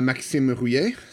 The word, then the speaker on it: Maxime Rouillet: maxime rouillet so if you, you want to say it properly so that's more or less like it